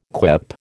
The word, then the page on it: quip: American English